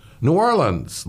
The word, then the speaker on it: New Orleans: New Orleans. That's how they pronounce it. New Orleans, like Savannah, another bigger party in the street.